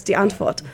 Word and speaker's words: Die Antwoord: I say it like a German, so it's Die Antwoord.